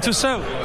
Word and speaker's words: Tussauds: Madame Tussauds.